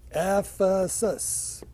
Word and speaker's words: Ephesus: Okay. So, Ephesus. By the way, how do I know that's how it's pronounced? – We don't. – Well, we do. But we only know that's how it's pronounced today, because that area still is called or referred to as Ephesus because they know where it is. So that doesn't mean that's how it was pronounced 2,000 years ago.